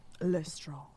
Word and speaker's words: Lestrange: Why do we say her name like that? – Because Jim Dale says it like that. – Lestrange.